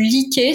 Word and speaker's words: Lykke: Ça s'appelle le livre du Liké. Ça s'écrit L-Y-K-K-E.